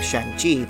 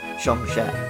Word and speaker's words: Shang-Chi: I will apologize that it's probably not pronounced Peng, just like how we were all saying Shang-Chi, but it's actually pronounced Shang-She, but I don't know how to pronounce it.